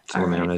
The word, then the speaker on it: Silymarin: It's Silymarin. Am I saying that right? – Mm-hmm. – Okay. – Silymarin is a very, very good one.